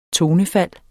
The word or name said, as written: tonefald